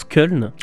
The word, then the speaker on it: Köln: Et je te remercie de m'apprendre qu'on prononce Köln. – C'est Köln, oui. – Voilà, voilà. J'ai toujours appelé ça Colm.